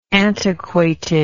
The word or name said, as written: antiquated